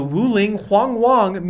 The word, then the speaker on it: Wuling Hongguang: Yeah, the Wuling Hongguang Mini EV.